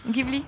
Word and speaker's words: Ghibli: Ghibli.